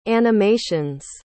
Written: animations

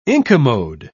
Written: incommode

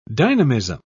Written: dynamism

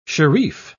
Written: shereef

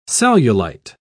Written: cellulite

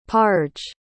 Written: parge

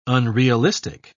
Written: unrealistic

